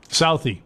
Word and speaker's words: Southie: Southie, is that how you say it? – Southie. – You got to say Southie. – Southie. – Yep. – Southie. You got to say it like Boston exit.